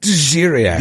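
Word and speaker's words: D'ziriak: Am I saying that right? – Djiriak.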